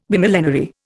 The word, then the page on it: Bimillenary: Indian